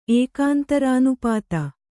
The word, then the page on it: ಏಕಾಂತರಾನುಪಾತ: ēkāntarānupāta